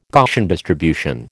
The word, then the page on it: Gaussian distribution: American English